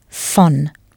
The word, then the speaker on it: von: For of, in this sentence, you'll use fon, V O N pronounced fon.